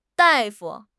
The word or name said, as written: dai4 fu5